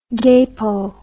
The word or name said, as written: gapo